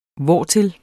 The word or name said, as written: hvortil